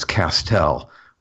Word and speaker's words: Castel: I usually do this with radio people and send them the phonetic pronunciation, but my name is Castel with the emphasis on the second syllable.